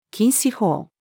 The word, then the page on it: 禁止法: female